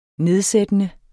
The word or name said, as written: nedsættende